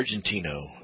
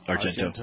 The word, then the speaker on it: Argento: Is it Argento or Argentino? – Argento.